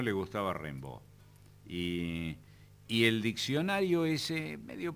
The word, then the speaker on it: Rimbaud: Rimbaud.